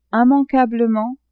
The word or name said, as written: immanquablement